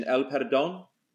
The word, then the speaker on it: El Perdón: Now, you might have to help me with the pronunciation, El Perdón. – That's a great song. – Yeah. – Oh, did I say it right? – Yeah, perfect.